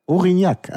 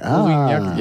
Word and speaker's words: Aurignac: Such mal nach Aurignac. – Aurignac, Aurignac, ja. – Ja, Aurignac.